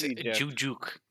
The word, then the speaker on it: Juszczyk: Kyle Juszczyk. – Juszczyk? – Oh, it's Juszczyk.